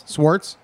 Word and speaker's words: Swartz: Jeff Swartz. Nope. Is that pronounced? – Swartz. – Swartz? – Yep, no CH.